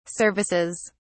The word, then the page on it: services: British English